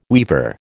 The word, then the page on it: weaver: American English